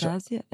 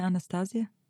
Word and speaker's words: Anastazja: Anastazja, Emilia Anastazja.